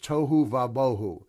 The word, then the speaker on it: tohu vabohu: So the Hebrew word without form and void are rhyming words, and it's pronounced tohu vabohu.